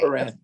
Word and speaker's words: Quran: The Quran? Am I saying that right? Nobody want to say anything? – Yes. – Okay. – Quran.